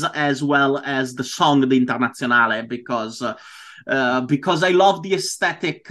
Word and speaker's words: Internazionale: Now, how do you pronounce it? You pronounce it the Italian way. – I pronounce it the Italian way because I grew up calling it like that, so the Internazionale.